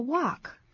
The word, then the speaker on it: walk: Let's go for a walk. – 咱们去散个步吧。 Let's go for a walk. 这个也都是固定用 go for. 但谈到这个 walk, 可别念成 walk, 或者是 work, 让人搞不清楚。在美式英语这个散步啊，我们先把它拼一下好吗？ – W-A-L-K. W-A-L-K, walk. 请注意 L 是不发音的 walk. Walk.